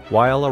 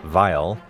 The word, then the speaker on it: Weill: In 1935, after two unhappy years in Paris and London, Weill arrived in New York, applied for U.S. citizenship, and reinvented himself as a successful Broadway composer, insisting on anglicizing the pronunciation of his last name from Weill to Weill, and refusing to even speak German.